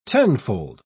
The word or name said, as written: tenfold